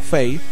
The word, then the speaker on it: Fae: Durante la conversación, Lauren le explica que las personas que conoció no son humanos, sino más bien una cepa evolucionaria diferente llamada Fae, o Fae, la pronunciación correcta.